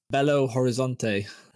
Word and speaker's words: Belo Horizonte: obviously the map of Belo Horizonte.